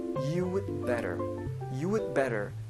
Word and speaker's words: you'd better: 이때 여러분들 너무 그 드 발음 세게 해서요, you do better이라고 읽지 않으시기를 부탁을 드릴게요. 조금 세련되게 읽고 싶다고 생각이 되시면요, 요 D 발음을요, 우리나라 말 시옷 받침 정도로만 발음해 주셔도 좋아요. 그래서 you'd better 정도로 읽어보시면 좋겠습니다. 읽어보자면 그래서 you'd better be careful when you drive 정도의 뜻이 되죠.